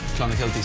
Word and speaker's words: Clonakilty: Irish tea bags or Clonakilty sausages.